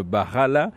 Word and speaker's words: Barala: Mais je vais directement lui demander de corriger la prononciation de Barala avec l'accent correct portugais. – Barala.